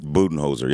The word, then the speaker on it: Budenholzer: But yes, the Milwaukee Bucks have let go of Coach Mike Bud, but Budenholzer, is that correct pronunciation? Budenholzer, yep.